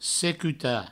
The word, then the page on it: secutar: secuta